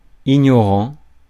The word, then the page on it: ignorant: France; [i.ɲɔ.ʁɑ̃]